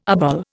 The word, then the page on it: obol: Indian